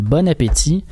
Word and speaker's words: Bon Appétit: Bon Appétit, à prononcer à l'anglaise parce que c'est un channel américain.